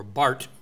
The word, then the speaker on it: Barth: This was developed by a man named Karl Barth, or Barth, the German pronunciation.